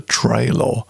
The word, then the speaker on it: Trailor: From an album Man Child, Herbie Hancock, and a track called The Trailer. And that's Trey, I pronounce it that way because it's spelt T-R-A-I-L-O-R.